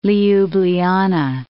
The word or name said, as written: Ljubljana